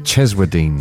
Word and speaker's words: Cheswardine: Right, Karen, in a place I can't pronounce, anybody like to help me? – Cheswardine.